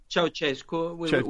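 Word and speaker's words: Ceaușescu: is it Ceausescu? Is that how you pronounce it? – Ceausescu, we say in Italy, but I guess Yeah.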